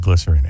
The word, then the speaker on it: Glycerine: The Quake 102.1, it's Bush and Glycerine, or Glycerin? Well, they pronounce it Glycerine.